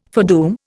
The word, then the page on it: foredoom: Indian